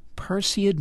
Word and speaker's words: Perseid: You must be a regular viewer of this Perseid meteor shower. Am I pronouncing that correctly? – Yeah. Yes, you are.